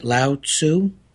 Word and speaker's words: Lao Tzu: It's a quote from Lao Tzu. Is that pronouncing that right, Lao Tzu? – I believe so, yes. – T-Z-U.